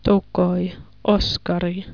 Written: Tokoi Oskari

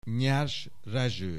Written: Nyers Rezső

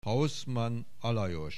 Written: Hauszmann Alajos

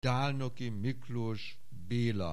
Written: Dálnoki Miklós Béla

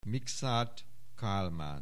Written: Mikszáth Kálmán

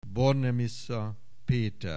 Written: Bornemisza Peter

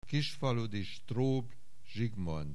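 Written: Kisfaludi Strobl Zsigmond

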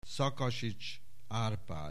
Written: Szakasits, Árpád

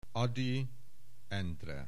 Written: Ady, Endre

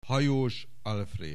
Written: Hajós, Alfréd

